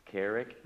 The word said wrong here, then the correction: Chiari: am I saying that right? – Chiari, right, it's a Chiari tumor, and they've been working with that for several years, so you probably remember that.